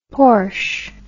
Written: porsche

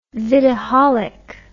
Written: vidaholic

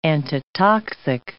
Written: antitoxic

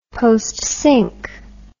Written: postsynch

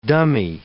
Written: dummy

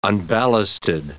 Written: unballasted

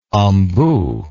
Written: ombu

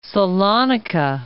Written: Salonika

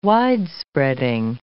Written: wide-spreading

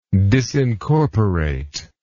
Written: disincorporation

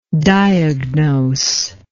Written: diagnosable

